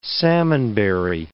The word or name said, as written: salmonberry